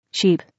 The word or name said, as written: sheep